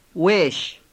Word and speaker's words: ويش: ويش. – It is composed of و and, which is pronounced together with the next word, ايش.